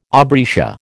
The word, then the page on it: aubrietia: American English